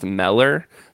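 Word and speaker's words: Müller: And he said, no, it should actually be pronounced Meller.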